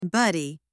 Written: buddy

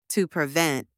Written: to prevent

